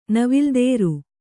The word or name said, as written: ನವಿಲ್ದೇರು